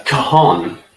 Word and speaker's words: cajon: It's a cajon. It's spelled C-A-J-O-N, with a silent J.